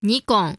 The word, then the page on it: Nikon: ɲiꜜkoɴ